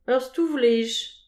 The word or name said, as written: ur stouf lej